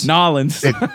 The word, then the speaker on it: New Orleans: Is it New Orleans or New Orleans? – Narlins. – It depends where you're from.